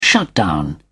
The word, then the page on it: shutdown: British English